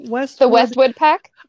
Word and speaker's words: Westwood Pack: Westwood Pack? – I almost was like Westward. I'm like, that's not it.